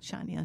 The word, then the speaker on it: Chania: Chania is how you say it.